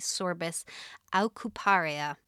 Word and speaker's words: Sorbus aucuparia: But in the UK, we tend to have the Sorbus aucuparia. – Ooh. – Pronunciation? Question mark. – No, your pronunciation sounds good.